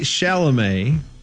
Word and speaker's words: Chalamet: Actor Timothy Chalamet...